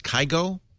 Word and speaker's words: Kygo: It's pronounced Kygo.